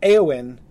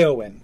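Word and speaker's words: Éowyn: So I agree, like, Eowyn is really a two syllable name.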